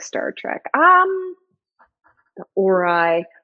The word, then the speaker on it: Ori: It is pronounced Ori.